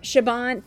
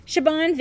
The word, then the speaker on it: Siobhan: Siobhan. – Thank you. Siobhan Vivian.